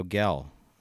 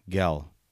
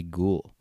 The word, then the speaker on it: Guel: By the way, my name is Mario Gell. Yes, Gell, like Miguel. My last name is spelled G-U-E-L. You can also say Ghoul, or you can just call me Mario.